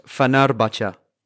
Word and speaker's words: Fenerbahçe: It's pronounced Fenerbahçe.